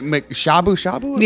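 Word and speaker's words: shabu-shabu: Is it Shabu Shabu? – Yeah.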